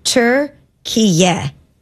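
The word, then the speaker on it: Türkiye: official pronunciation of go. – the country's recognized name is Turkiye.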